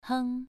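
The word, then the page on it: heng: tone 2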